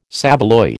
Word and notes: saveloy: American English

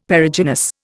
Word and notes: Perigynous: Indian

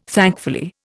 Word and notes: thankfully: Indian

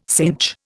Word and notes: cinch: Indian

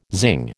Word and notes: zing: American English

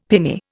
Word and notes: pinnae: Indian

